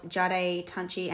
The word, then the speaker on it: Jade Tunchi: I was scrolling Instagram and came across Jade Tunchi, and yes, that's how you pronounce her name according to her.